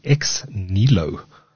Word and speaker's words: Xneelo: And that is Xnelo, pronounced or spelt X-N-E-E-L-O.